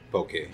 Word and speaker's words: poke: So let's talk about poke, for the correct way to say poke. – Poke. – Yeah.